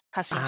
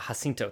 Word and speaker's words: Jacinto: We learn that he was murdered by Jacinto. – It's a soft C, Jacinto. – Ah, Jacinto, thank you.